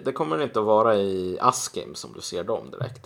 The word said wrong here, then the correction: Askim: Det, det uttalas Askim. – Askim, okej, okay, förlåt.